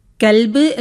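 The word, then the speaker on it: കൽബ് (كلب): അതുപോലെ ക എന്ന അക്ഷരത്തിന് രണ്ട് ഉച്ചാരണങ്ങൾ വരുന്നു. ഒന്ന് ക, ഒന്ന് ഖ. കൽബ് എന്ന് പറയുമ്പോൾ നായ എന്നർത്ഥം വരുന്നു. എന്നാൽ ഖൽബ് എന്ന് വരുമ്പോൾ അതിന് ഹൃദയം എന്നാണ് അർത്ഥം വരുന്നത്.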